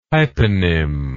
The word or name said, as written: hyponym